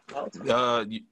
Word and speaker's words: Baltimore: Baltimore. We don't put the T in it. It's more of a D sound. – Oh, okay. Okay. So, I ain't gonna try it. – Yeah, but yeah, from Baltimore.